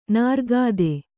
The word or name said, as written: ನೀರೋಗತೆ